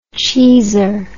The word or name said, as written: cheeser